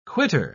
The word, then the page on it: quittor: British English